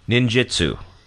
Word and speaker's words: Ninjutsu: Ninjutsu. – Nope, you said it perfectly.